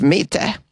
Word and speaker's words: Mitte: Mitte district. Did I say that correctly? Mitte? Yes, Mitte.